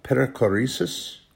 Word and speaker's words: perichoresis: But anyway, this is the way that the Father, Son, and Holy Spirit is called perichoresis, I think I'm saying it right.